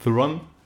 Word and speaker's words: Theron: Ja, Charlize Theron ist Theron.